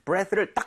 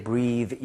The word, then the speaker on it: breath: 그래서 발음할 때 breathe 이게 아니라 breath.